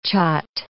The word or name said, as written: kyat